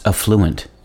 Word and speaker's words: affluent: Today's word is affluent, also sometimes pronounced affluent and spelled A-F-F-L-U-E-N-T.